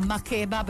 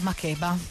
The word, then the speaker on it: Makeba: Su Radio Marte, beh, bisogna spostare l'accento. In realtà lei si chiama Makeba perché sa che questa canzone è dedicata a Miriam Makeba.